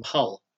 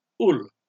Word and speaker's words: Hull: You will probably realise from my accent that, unlike my other colleagues, I don't originate from Hull, or as the locals pronounce it, Ull.